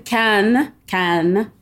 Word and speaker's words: Cannes: Is it Cannes or is it Con? How do I properly pronounce this film festival? – It is the Cannes Film Festival. And Caen, C-A-E-N, is a city that's in Normandy where it's cold and they make apples and cider.